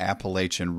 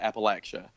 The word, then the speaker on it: Appalachia: I pronounce it Appalachia. – Mm-hmm. – And there's a reason for that.